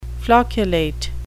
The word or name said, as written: flocculate